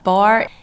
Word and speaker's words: barre: I know some people pronounce it barre, but it's actually pronounced bar, but you guys corrected us before we started.